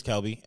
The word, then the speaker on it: Kelby: I'll say, like, my name's Kelby, and they'll be like, okay, Mr. Kerbin.